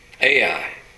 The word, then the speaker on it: Ai: I guess that's pronounced like it's spelled, Ai.